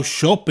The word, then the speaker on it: siop: Spelt double F-A-T-R-I. Dwi'n gweithio mewn ffatri. Dwi'n gweithio mewn ffatri. Dwi'n gweithio mewn ffatri. – Now, shop in Welsh is an easy one because it's said exactly the same way in English and Welsh. Shop. But it's spelt differently. It's spelt S-I-O-P. Shop.